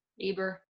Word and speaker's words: Uber: How do you pronounce it? – Eber. – Great. It's spelled U-B-E-R, and I knew I was going to say it wrong.